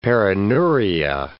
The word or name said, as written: perineuria